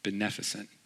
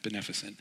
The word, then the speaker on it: beneficent: beneficent. Beneficent is the right way to say that, beneficent. And it is the right way to say it because I looked it up. So if you think I said it wrong, you don't know this word.